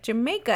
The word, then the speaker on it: Jamaica: So Jamaica, I feel like I'm still saying it wrong, is supposed to be pronounced like Jamaica, as if you're saying it in an English accent,